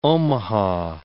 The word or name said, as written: Omaha